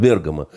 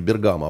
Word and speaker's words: Бергамо: И он говорит, это вы все время говорите Бергамо. А правильно говорить Бергамо, так сказать.